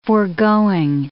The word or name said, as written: foregoing